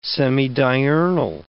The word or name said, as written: semidiurnal